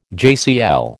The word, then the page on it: JCL: American English